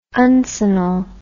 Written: uncinal

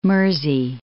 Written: Mersey

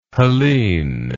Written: helene

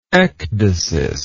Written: ecdysial

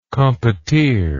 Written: compotier

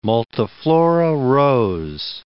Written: multiflora rose

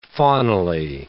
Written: faunally

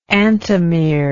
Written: antimerism

